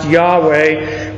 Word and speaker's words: Yahweh: Modern translations think it's pronounced Yahweh. We really don't know. We know that Jehovah's wrong because